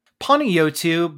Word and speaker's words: Panagiotu: Did I say that right? – Panagiotu, but you know what? You did a lot better than most, so I will let it slide for today.